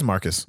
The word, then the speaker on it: Marquis: No, it's Marquis. – To the point where it seems like bad acting. – No, it is Marquis. – Yeah, you call him Marquis. – It's spelled Marquis. – It's spelled Marquis. – Is it Marquis? – Yeah, it's Marquis.